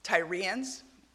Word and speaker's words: Tyrians: how do you say it? Tyrians. Tyrians. Thank you.